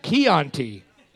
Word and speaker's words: Chianti: It's pronounced Chianti. – I'm tired of the fucking English language. Why they put an H in there? If you don't fucking announce, pronounce the H.